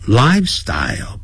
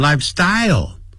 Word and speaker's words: lifestyle: 好 ,lifestyle, 不要念成 lifestyle, 重音在第一个音节。